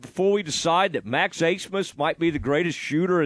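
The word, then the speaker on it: Abmas: It's A-B-M-A-S, but it's pronounced Asemus.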